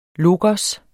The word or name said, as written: logos